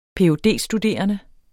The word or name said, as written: ph.d.